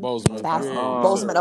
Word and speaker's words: Boseman: Boseman. – Okay, Chadwick Boseman.